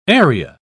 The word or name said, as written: area